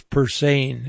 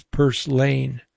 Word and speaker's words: Purslane: I thought it was Purslane. It's Purslane, like purse, L-A-N-E.